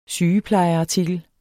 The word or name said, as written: sygeplejeartikel